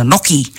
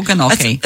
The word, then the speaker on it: gnocchi: Is that how you say it? Do you say gnocchi or gnocchi? – As far as I know, the G's silent, but don't quote me on that.